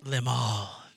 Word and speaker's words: Limahl: His name is Lim. I don't know. How do you pronounce it? It's Limahl. – Limahl.